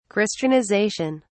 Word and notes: christianization: British English

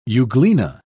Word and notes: euglena: British English

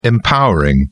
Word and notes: empowering: British English

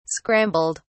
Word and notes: scrambled: British English

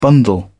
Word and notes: bundle: British English

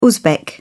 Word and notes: uzbek: British English

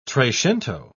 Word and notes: trecento: British English

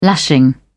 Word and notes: lashing: British English

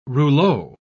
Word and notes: rouleau: British English

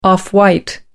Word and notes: off white: American English

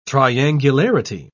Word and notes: triangularity: British English

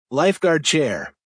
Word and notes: lifeguard chair: American English

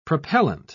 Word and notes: propellent: British English

